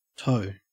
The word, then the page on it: toe: Australian English